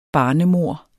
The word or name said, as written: barnemord